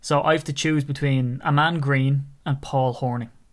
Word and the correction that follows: Paul Hornung: Or Paul Hornung, I should probably pronounce it correctly instead of Horning, but it's just my Irish accent.